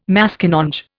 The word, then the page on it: Maskinonge: Indian